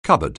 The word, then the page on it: cupboard: anglais